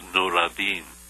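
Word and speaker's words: Nooradin: N-O-O-R. Actually, it's Nuradin. – N-U? R-A-D-D-I-N? – N-O-O-R-A-D-I-N, I guess. Or D-E-E-N.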